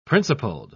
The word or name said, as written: principled